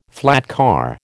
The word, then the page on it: flatcar: American English